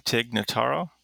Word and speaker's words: Tig Notaro: Tig Notaro. Is that how you say it? – Yep.